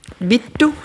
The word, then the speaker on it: Vittu: Ja, det er fordi Vitu, som det udtales på grønlandsk, øh, er Vitus på grønlandsk.